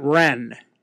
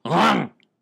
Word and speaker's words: Rennes: It's I would have pronounced it Rennes. – Okay. – But when I spoke to the people who live there, they apparently pronounce it Rang.